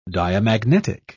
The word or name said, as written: diamagnetic